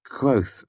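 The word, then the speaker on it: Kvothe: "It's Quoth, actually," I said absentmindedly. "The E is silent."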